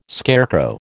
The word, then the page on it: scarecrow: American English